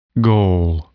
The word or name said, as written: goal